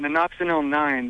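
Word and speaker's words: nonoxynol-9: Yeah, I got just a little bit of a random question about Nanoxinil 9, the spermicide.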